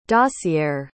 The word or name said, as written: dossiere